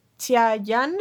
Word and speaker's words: Xia Yan: que se llama Xia Yan, ¿la estoy pronunciando bien? – Creo que es Xia, Xia, Xiayan. Es como una CH rara la X.